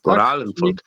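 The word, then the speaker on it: Goralenvolk: No właśnie i mm, film, który jest bardzo, bardzo kontrowersyjny, bo oto Goralen Volk. dobrze to wymawiam? Tak? – Goralen Volk. tak, dobrze czyli...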